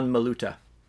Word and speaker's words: Maluta: Comment on dit ça, son nom C'est Sean Maluta.